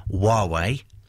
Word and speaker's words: Huawei: This particular one is manufactured by Huawei. That is how you pronounce it, which is a Chinese communications company.